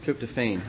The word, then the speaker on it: tryptophan: Recuperating from tryptophan. – Is it tryptophan? How do you say it, chemist? Tryptophan.